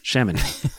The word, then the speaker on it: Chamonix: Chamonix? – Is that how we pronounce it? – Yes. Mm-hmm. – Yes. Chamonix.